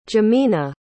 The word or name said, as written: Jemina